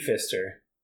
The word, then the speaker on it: Pfister: Pfister, so his name is Pfister with a silent P at the beginning. So I think, wait, so, wait, it's so like P-H, P-H? – No, it's just P. Pfister.